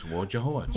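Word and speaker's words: Wojciehowicz: Was Wojciechowicz, and they'd always say to him, "How do you pronounce it?" And just like it sounds, Wojciechowicz.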